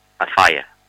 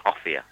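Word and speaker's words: Offiah: A fire. – Yeah. – But he's actually pronounced Offia. – Offia, yeah. I was told we had to call him Offia.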